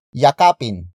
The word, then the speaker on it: yakap: Yakapin mo siya. Yakapin mo siya. Yakapin mo siya. It means embrace that person. The focus is on that person. Word for word it's yakapin mo siya.